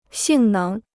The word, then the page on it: 性能: xìng néng